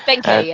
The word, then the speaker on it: Benkei: Was it pronounced Benkei or whatever? – Benkei, yeah.